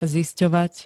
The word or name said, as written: zistovat